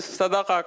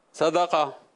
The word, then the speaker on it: صدقہ: درست تلفظ بتا دیجئے. صدقہ عربی کے حساب سے صدقہ ہے یا صدقہ ہے؟ – عربی میں صدقہ کہتے ہیں, صدقہ.